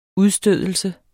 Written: udstødelse

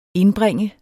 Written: indbringe

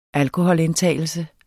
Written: alkoholindtagelse

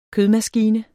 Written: kødmaskine

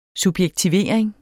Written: subjektivering

